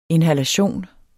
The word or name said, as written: inhalation